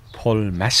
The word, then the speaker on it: Paul Mas: Paul Mas, skal vi ikke kalde ham det? – Jo. – For i Sud de France, der udtaler man jo S'et til sidst.